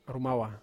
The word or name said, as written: roumawa